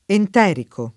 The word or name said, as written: enterico